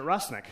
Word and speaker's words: Rusnak: Now, my last name, I pronounce it Rusnik, because that's what I was told. But if you look at it, it's A-K at the end, and I was my teacher was very insistent it must be Rusnak, with a hard ah sound.